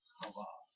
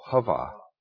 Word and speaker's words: Chava: And we build on that a woman has definition in terms of a man, but then Eve has another name, Shiva. – Hava. – Oh, Hava. The sea is silent? – Well, it's a guttural. It's like Shiva. Shiva. – It's not the easiest letter to pronounce.